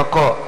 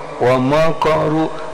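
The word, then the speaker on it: Wamakaru (وَمَكَرُوا): Jadi jangan mako. Wamakaru. Berarti kaknya terpengaruh huruf ro. Ro itu kan dibaca tebal. Berarti kaknya maka aja. Maka. Wamakaru. Nah, jangan wamakaru ikut ko. Jangan. Ayo lagi. – Wamakaru makarung kumbaro.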